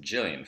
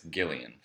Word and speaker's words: Gillian: And also the author of the book, who I would say is pronounced Gillian Flynn, but apparently it's Gillian Flynn.